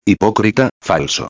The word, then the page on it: hipocrita falso: espanhol